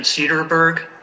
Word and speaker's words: Cederberg: My name is Dan Cederberg. Last name is spelled C-E-D-E-R-B-E-R-G.